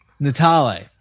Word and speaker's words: Natale: And with us today from the You're Missing Out podcast, a longtime correspondent of ours, Michael Natale. – Thank you, guys. – Not like Natalie the first time I pronounced it on the pod.